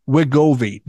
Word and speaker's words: Wegovy: And the drug in question is called, I think it's pronounced Wegovy, W-E-G-O-V-Y.